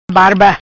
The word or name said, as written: Barba